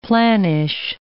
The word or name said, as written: planish